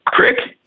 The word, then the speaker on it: creek: Since you're from Delco, how do you pronounce the word C-R-E-E-K? – Crick. – You win the prize. – And W-A-T-E-R. – What was it? – W-A-T-E-R.